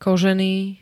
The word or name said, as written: kožený